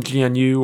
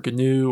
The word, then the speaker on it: GNU: A lot of really big projects, we mentioned GCC, use the GPL license, and most of the software from GNU or GNU or NU, depending on how you pronounce it, I've seen it always, is licensed under the GPL, if not all of it, in fact.